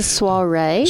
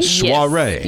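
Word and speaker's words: soirée: Did I say that right? Because I have a hard time with that word for some reason. – I think it's a soiree. – Soiree. – Yes. – Soiree.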